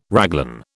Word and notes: raglan: British English

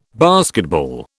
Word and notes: basketball: British English

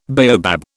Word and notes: baobab: British English

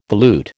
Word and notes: volute: American English

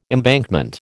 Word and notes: embankment: American English

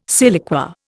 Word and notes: siliqua: Indian

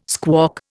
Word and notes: squawk: Indian

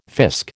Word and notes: fisk: American English

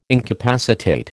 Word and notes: incapacitate: American English

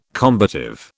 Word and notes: combative: British English

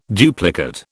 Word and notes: duplicate: British English

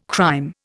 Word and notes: crime: Indian

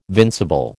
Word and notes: vincible: American English

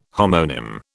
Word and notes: homonym: British English

